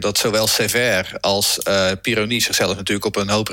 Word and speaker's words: Pironi: Dan hebben we nog Didier Pironi, zeg ik dat goed? – Ja, Pironi.